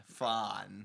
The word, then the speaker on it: Phahn: This one is from Elton Fon. From Fon? – It's spelled Phahn. Fon.